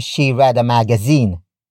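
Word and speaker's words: magasin: Magazine, ang, binasa, nya.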